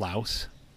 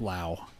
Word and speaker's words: Laos: I think there are places in like Laos, you know, I used to call it Laos, but that's not cool. I don't know what I'm, you know, you have to pronounce it the right way. It's Laos.